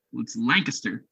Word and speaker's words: Lancaster: Yeah, there's a couple different Lancasters throughout the country, but Pennsylvania is the only one that pronounces it Lancaster. Everybody else is Lancaster.